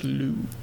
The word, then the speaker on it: Liu: Lucy Liu. It's pronounced Liu.